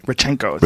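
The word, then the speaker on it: Rodchenkov: The Rodchenko one being the – am I saying it even right? How do you say it? – Rodchenko. – Rodchenko. – Yeah.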